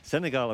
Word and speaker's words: Senegal: Senegal, I've been practicing all day.